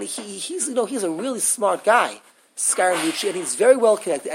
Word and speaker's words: Scaramucci: Scaramucci.